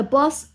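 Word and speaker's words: boss's: 比如说 boss，boss 老板啊，这个老板的房间，老板的办公室，我们既可以用 the boss 一撇 office，或者是 The boss office 读音就是读一个 s 就好了，不用读两个。 The boss office 或者是 the boss 加上一撇 s office，但是读音都是 the boss office。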